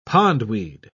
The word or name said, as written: pondweed